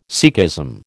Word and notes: Sikhism: American English

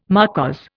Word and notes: marquise: Indian

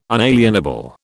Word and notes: unalienable: British English

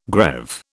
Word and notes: greave: British English

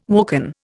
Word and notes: woken: Indian

